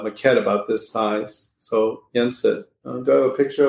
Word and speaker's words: Yensid: Yensid, am I saying that right? – Yeah. – Disney backwards.